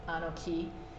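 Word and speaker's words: Anoki (אָנֹכִי): It has to do with Anoki is like I am. Anoki. So it has an E sound at the end.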